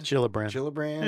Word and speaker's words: Gillibrand: Gillibrand. It's a soft G.